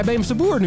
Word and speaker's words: Beemsterboer: Ik denk, ik denk mijn naam zal weer verkeerd uitgesproken worden, maar hij zei Beemsterboer nu, of niet? Heeft hij het echt een keer goed gedaan? Oh, wat fijn jongens. Het heeft het hele seizoen geduurd, maar eindelijk hebben we een goede jingle waarin mijn, eh, waarin mijn naam eindelijk goed wordt uitgesproken.